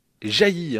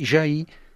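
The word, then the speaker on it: Jaÿ: On oublie aussi, souvent également, que Jet s'écrit avec un tréma sur le Y et se prononce Jailly. Hein. – Normalement, oui. Au pays, c'est-à-dire à Samoin, d'où elle est originaire, on dit Jailly.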